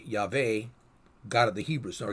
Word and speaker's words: Yahweh: with that I pronounce Yahweh.